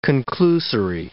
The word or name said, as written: conclusory